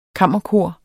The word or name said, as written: KAMMERKOR